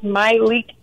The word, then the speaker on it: Malik: It is Malik Teal. – My, with an emphasis on my.